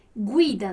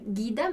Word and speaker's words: guida: Guida, né? Então a gente non va a dire guida, ma guida. Guida, dillo chiaro? Guida, perfetto.